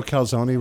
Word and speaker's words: calzone: Hey, did you know calzone rhymes with baloney? – Almost.